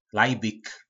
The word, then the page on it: Lajbik: po nasimu